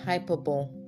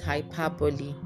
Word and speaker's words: hyperbole: Hyperbole. That's what a lot of Nigerians say. But the real pronunciation is hyperbole. All right, the E sound at the end is always articulated because it's a French word.